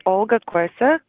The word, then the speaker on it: Olga Korsak: Hi, my name is Olga Korsak. In the music world, I'm known as Liva, L-I-V-V-A, and thank you so much for having me.